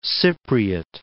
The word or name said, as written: Cypriot